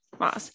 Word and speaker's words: Maas: Yeah, because I mean, how do you say Sarah J. Maas's last name? Do you say Mass or Moss? I've heard it several times. Moss.